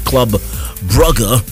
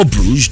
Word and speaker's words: Brugge: Aston Villa beat Club Brugge, or Bruges, depends on how you'd like to pronounce it, 3-2.